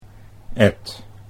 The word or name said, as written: Ed